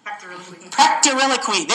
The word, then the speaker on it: pectoriloquy: What's it called? Plectorictory. Pectoriloquy. There we go.